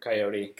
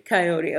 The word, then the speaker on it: Coyote: And I don't know how you pronounce his last, Peter Coyote. – Coyote.